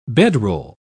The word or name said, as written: bedroll